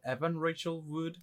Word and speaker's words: Evan Rachel Wood: Is it Evan Rachel Wood? – Yeah.